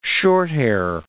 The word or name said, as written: shorthair